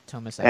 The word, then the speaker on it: Tomasi: Tomasi? Is that how you pronounce his name? – Yes. Tomasi.